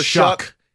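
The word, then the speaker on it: Shough: I thought it was Tyler Shaw, but it's Shuck. – It's Shuck, which rhymes with suck.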